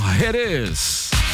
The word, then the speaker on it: Jerez: O Eduardo Jerez, ou Jerez, como se fala a cidade de Jerez de la Frontera.